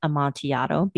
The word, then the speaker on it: Amontillado: And I am saying Amontillado because that would be the Spanish pronunciation where two L's make a Y.